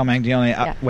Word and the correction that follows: Manganiello: Wait, did I say it right? – No one says it right. – Not even Joe. – No, he didn't even know how to say it. Manganiello. – Manganiello. Thank you. Manganiello. For some reason, I want to say Manganiello, but Manganiello.